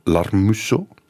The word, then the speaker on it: Larmuseau: Het gaat vandaag en Weet ik Veel over stamboomonderzoek met professor genealogie Maarten Larmusso. Ja. Met de klemtoon op de lar.